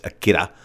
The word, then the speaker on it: Akira: How Japanese pronounce R is kind of more like a DT kind of thing, so it's Akira.